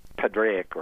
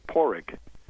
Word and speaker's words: Padraig: P-A-D-D. – Yes, with D. Right, because it's based on the original Irish, P-A-D-R-A-I-G. You're not knowing how to pronounce the Irish, you would say Padraic or something like that. The Irish would probably pronounce it Porrick.